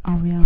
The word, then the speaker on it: Ariane: There's so many different ways of A R I A N E. – I would say Ariane.